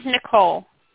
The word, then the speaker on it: Nicole: My name is Nicole. – Nicole, okay, very good. N-I-C-O-L-E, is it? – Yeah, mm-hmm.